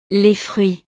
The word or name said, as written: les fruits